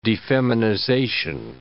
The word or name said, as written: defeminization